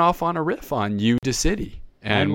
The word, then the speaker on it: Udacity: I guess it's supposed to be pronounced Udacity, UDACIT. Again, a play on I guess audacity, but it's like Udacity.